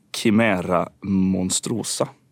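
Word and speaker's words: Chimaera monstrosa: Och den heter alltså då Chimera monstrosa, om jag uttalar det på svenskt latinskt vis.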